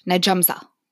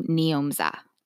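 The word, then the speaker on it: Njomza: Oh, I'm sorry. Nyomza. Okay, so you pronounce her name Nyomza. And that's N-J-O-M-Z-A.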